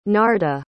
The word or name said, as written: narda